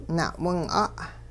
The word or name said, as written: na'wing'a'